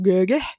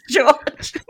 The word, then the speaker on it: George: Ja miten se lausutaan enku, niinku koska niin Göge. – Gurge? – Eli, eli siis George. Göge eli siis George.